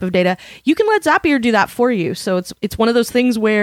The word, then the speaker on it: Zapier: And our sponsor today is Zapier. And yes, it rhymes with happier. And the best way I have found to describe Zapier to people is that it hooks your stuff to all your other stuff.